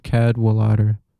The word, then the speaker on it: Cadwallader: Before that, we had Casual Discussion in a Dome Between Two Temples by Algernon Catawaller. That's off their 2008 Some Kind of Cadwallader. Man, I always say it wrong. Algernon Cadwallader.